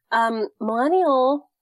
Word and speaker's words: Millennial: So Millennial, the hashtag is silent. It's very important that you know that.